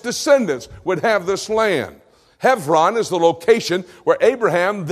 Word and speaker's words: Hebron: Now it's pronounced Hebron. I am pronouncing it correctly according to the Hebrew. You might pronounce it Hebron, H E B R O N, but the B has a V sound in Hebrew. Hebron.